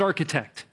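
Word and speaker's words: architect (ἀρχιτέκτων): And the Greek word for master builder there is the word architect. It's pronounced architect.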